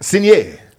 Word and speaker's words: Sinner: Is it Sinner or is it like, is there an accent on it? – Sinner. – Yeah, it's Sinner.